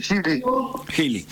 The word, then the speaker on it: Geely: Mira, nuestro interés es conocer un poco más sobre las dos SUVs que han traído de la firma china Geely. Creo que se pronuncia así. – Exactamente, Geely.